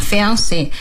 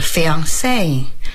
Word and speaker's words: fiancé: 啊、说的是在英语里边很多法语词就原模原样的端来，所以呢就像这样 Fiancé 也就这样端来了。而且法语的发音重音往往在最后一个。要是真正的法语呢是 fiancé 会说这样的，但是到英语里边就 fiancé 就变得稍微那个往前靠了一些。